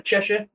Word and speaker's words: Cheshire: Cheshire.